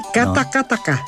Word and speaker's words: Katakataka: Vamos talvez escutar aqui uma versão de Katakata. – Sim, Katakataka. – Katakataka. – Isso. – Falta uma sílaba.